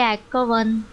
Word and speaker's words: Akhavan: And then her last name is A-K-H-A-V-A-N. So how would you... – Ackhaven. – Anyway, I YouTubed it. Because you can go on YouTube to find out how to pronounce words. – Oh, my God. Yeah. – And so here it is. Do you reckon this is right? – Desiree Ackhaven. – Oh, I like that.